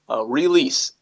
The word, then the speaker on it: Reelise: Release. – Okay. And it's spelled? – R-E-E-L-I-S-E. So it's Re and then her name, Elise.